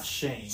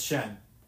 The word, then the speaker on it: Shen: Shen, it's Shen, it's Shen.